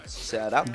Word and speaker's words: Ceará: Ceará.